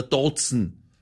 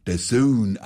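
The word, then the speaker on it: DAZN: Natürlich, man braucht kein Skü oder Dotzen. – Allmächt, heute wieder lauter Profis am Start. Das eine heißt nicht Skü, sondern Sky und das andere spricht man der Sohn aus.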